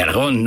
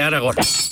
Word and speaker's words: Aragón: De Aragón, no de Aragorn. Aragón con acento en la O.